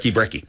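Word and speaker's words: Brekki: And it's spelled B-R-E-K-K-I. B-R-E-K-K-I. Brecky.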